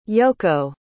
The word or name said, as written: yoko